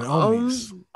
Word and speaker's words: Omies: Is it Omies? – I've been saying Omies. – Omies? – Yeah. – Like homies without the H. – Yeah. Yeah. Silent H.